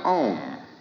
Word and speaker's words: Om: Now, this sacred syllable Om, which is repeated in all of the prayers of India, is composed of four sounds.